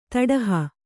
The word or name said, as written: ತೊಡಹ